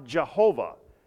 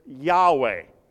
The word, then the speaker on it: Yahweh: Because they've read some guy that said that it's not pronounced, it's not Jehovah, that it's Yahweh. And that's the way it should be pronounced.